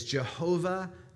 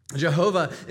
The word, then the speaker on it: Jehovah: It's essentially a modern mispronunciation. It comes from combining the consonants of Yahweh, YHWH, and the vowels of Adonai, AOAI. And so essentially, you think about it as Yehovah is what they thought it was, or Jehovah.